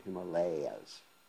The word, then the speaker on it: Himalayas: Correct pronunciation, the Himalayas. It was a long A, Himalayas. Himalayas. Anyway, so, see, Om we know about, that is A-U-M, that is Radha, Krishna, and the living entity, which is non-different than the holy name.